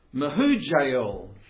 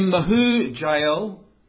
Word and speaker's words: Mahujael: Irad named his son Mahujael. Interesting name, four syllables. The last syllable is El. That's part of God's name, as in Elohim.